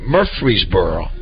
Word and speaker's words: Murfreesboro: When I say Murfreesboro, or Murfreesboro. What's the correct way to say it, Jamie? – Second way.